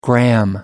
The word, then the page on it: gram: American English